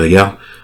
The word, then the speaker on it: H'ghar: En tout cas, on dit H'ghar, parce que je crois que dans la série, il dit Jaqen H'ghar. C'est vrai que quand tu lis ça et que t'as jamais entendu la prononciation...